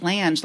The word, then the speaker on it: Lange: Yes, Lange, like flange.